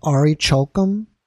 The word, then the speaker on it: Orichalcum: So this ring is made of a metal called Orichalcum. That's how you pronounce it.